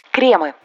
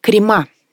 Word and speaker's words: кремы: Кремы. – Популярный вариант «крема». Неправильный. Во всех формах этого слова ударение сохраняется на корне. Попробуем закрепить с помощью рифмы. В корзиночках кремы, на бутербродах джемы.